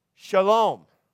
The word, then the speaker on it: shalom: And so in the ancient Hebrew pictures, the description of this word, which is pronounced in Hebrew, some of you know it, it's shalom. Shalom. Okay, so this has the SH sound, here's your L sound, and here's your M sound.